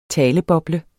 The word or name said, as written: taleboble